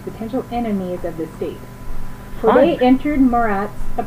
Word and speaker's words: Corday: Charlotte Cole. It's like the easiest thing to say, and I can't. It's fine. Charlotte Corday. Yeah, C-O-R-D-A-Y.